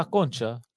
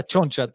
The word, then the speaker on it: concha: That they say la concha, la concha tu madre. They say they don't say la choncha tu madre. You know what I mean? – It's a hard C.